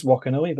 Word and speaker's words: Wakanui: the H is the correct spelling, and, and maybe one day it could change, but for now it remains Wakanui, but you can feel free to pronounce it with the H.